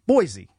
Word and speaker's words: Boise: I don't know if it's Boise or Boise. I know there's controversy as to how it's pronounced. – Boise.